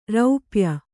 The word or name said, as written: ರೂಪಾಯಿ